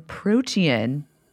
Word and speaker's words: Protean: Devin, you have a new piece in Protean magazine. Is that how you say it? – Mm-hmm. It's like a Greek style of protein.